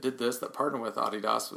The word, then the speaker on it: Adidas: If you're cool, you would pronounce it Adidas, which is actually the way you're supposed to pronounce it. For those who may not know what I'm saying, it's Adidas, but yeah, you got to pronounce it the right way.